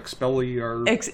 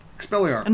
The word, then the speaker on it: Expelliarmus: Like Expelliarmus. – Expelliarmus.